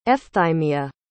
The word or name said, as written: efthymia